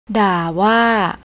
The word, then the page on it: darwa: thai